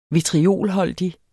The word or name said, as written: vitriolholdig